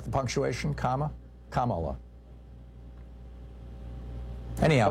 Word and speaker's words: Kamala: Fox News, and Trump mispronounces it as a way of slurring her. It's Kamala.